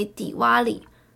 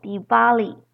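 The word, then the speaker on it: Diwali: 再来是最后一个节日，每年年底的重头戏，称为迪瓦里排灯节。迪瓦里呢，有人念作迪巴里 V 的音，或是迪帕瓦里。 Deep 的意思是光明或灯，而瓦里呢，则是排排的意思。中文呢，也将迪瓦里翻作其他的名称，有屠妖节啊、万灯节。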